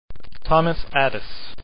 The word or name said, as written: Adès